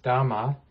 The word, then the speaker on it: dhamma: Sometimes you hear it as dhamma. That's the Pali version. And then it's pronounced dharma in Sanskrit.